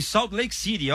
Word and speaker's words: Salt Lake City: Estou em Salt Lake City, ó. Sou palmeirense. Gostou da pronúncia, mano? Salt Lake City? – Foi bem, Salt Lake City.